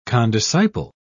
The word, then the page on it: condisciple: British English